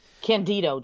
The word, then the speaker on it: Candido: Candido, Justin. – Candido. Candido. I'm sorry. That's right. – I always say that wrong.